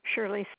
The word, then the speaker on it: Shirley: Yes, my name is, my email is number 2, Shirley Fetich. That's spelled S-H-I-R-L-E-Y.